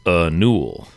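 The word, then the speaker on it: Niwl: Apparently, that's pronounced Anul.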